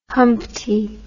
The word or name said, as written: humpty